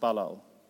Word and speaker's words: Balal: I looked it up, and apparently Babel is pronounced Bavel. And the word for confused is pronounced Balal.